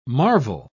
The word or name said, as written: marvel